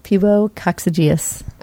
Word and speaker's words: pubococcygeus: So is it pronounced pubococcygeus? – Yes, pubococcygeus.